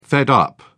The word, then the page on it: fed up: British English